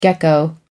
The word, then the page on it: gecko: American English